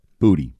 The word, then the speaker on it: Boutte: Boutte. B-O-O-T-Y is how you say it. – B-O-U-T-T-E is how it's spelled.